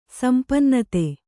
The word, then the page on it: ಸಂಪನ್ನತೆ: sampannate